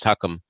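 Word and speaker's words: chuchem: And so they needed, they needed Irving Jacobson to be in Huckam, That's C-H-U-C-H-E-M, but it's pronounced Huckum. It's Yiddish for wise man.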